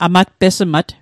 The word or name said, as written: amatpesmat